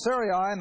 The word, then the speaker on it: Sarai: And Sarai, now I'm using that pronunciation because, see, her name is going to change to A-H in a little while.